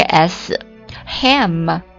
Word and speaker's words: sham: 来看今天的最后一个单词 sham。火腿前面加了一个 s， ham 加 s。读作 sham, sham, 很多同学应该知道这个单词的意思，对不对？